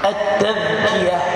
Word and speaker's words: التذكية: والتذكية بالذال المعجمة يعني المنقوطة، دال فوق نقطة اسمها الذال المعجمة.